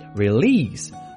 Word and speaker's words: release: 我们一个一个来看，第一个动词是我们今天要讲到的首个核心单词 release， release， R-E-L-E-A-S-E， R-E-L-E-A-S-E， release，后面的 S-E 发音是清辅音 S。